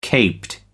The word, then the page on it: caped: American English